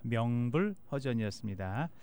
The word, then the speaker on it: 명불허전: 근데 제가 먹물허전으로 해가지고 혹시나 들으시는 분들이 이 먹물허전은 무엇인가 생각하실 텐데 정정하겠습니다. 명불허전이었습니다.